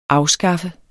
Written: afskaffe

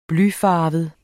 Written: blyfarvet

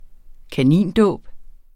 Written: kanindåb

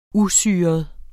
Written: usyret

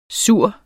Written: sur

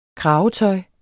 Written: kravetøj

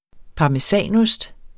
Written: parmesanost

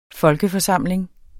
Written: FOLKEFORSAMLING

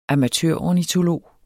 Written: amatørornitolog

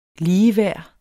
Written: ligeværd

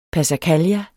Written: passacaglia

